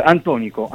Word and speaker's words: Antonico: Allora, un bel lavoro che esce proprio in questi giorni, un titolo, come lo diciamo, Antonico, come lo possiamo pronunciare? – Antonico. – Quindi è la dizione giusta.